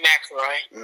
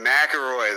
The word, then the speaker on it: McIlroy: Rory McIlroy. – McIlroy, there we go.